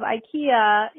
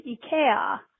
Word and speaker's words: IKEA: And I just wanted to let you know that the proper pronunciation of IKEA in Scandinavia is IKEA. The I sound is like an E sound here, so it's IKEA. At least that's how it's pronounced in Norwegian.